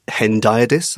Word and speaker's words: hendiadys: Ever since I read 1599 and you alerted me to this literary concept of hendiadys. Am I saying that right? – Yes, you are.